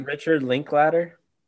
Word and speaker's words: Richard Linklater: But another one of mine, Richard Linklater.